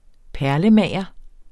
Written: perlemager

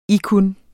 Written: ikkun

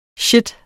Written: shit

